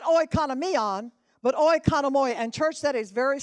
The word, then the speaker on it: oikonomoi: But our word for dispensation comes out of the Latin translation, and we pronounce it oikonomoi. If you that are writing, it's O-I-K-O-N-O-M-O-I, oikonomoi, which means, now watch me, to govern or steward.